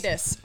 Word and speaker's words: Paulides: it, does anyone know what missing 411 Paulides. is? Paulides. I'm yeah, sorry. Dave Paulides. Okay, I mispronounced it.